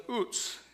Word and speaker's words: Uz: He dwelled in the land of Uz. That is how you say it. Aretz Uz, the land of Uz in Hebrew. Kind of like the potato chip, only with a long U. U-T-Z kind of thing.